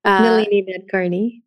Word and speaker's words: Nalini Nadkarni: Nalini Nadkarni.